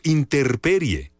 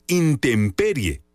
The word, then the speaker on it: intemperie: ¿Cómo se dice correctamente intemperie o interperie? La forma correcta de decirlo es intemperie, con M. La palabra interperie no existe en el diccionario.